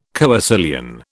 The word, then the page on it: coecilian: British English